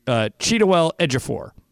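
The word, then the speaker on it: Chiwetel Ejiofor: That in this movie, Keira Knightley is married to Chidiwell Ejiofor.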